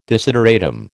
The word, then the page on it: desideratum: American English